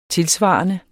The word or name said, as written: tilsvarende